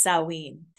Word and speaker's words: Samhain: But before that, it was actually called Samhain, which is spelled Samhain. And that is the Irish spelling of it, but it is pronounced Samhain.